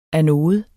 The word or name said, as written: anode